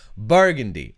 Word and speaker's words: burgundy: I was putting the emphasis on the wrong syllable. Burgundy.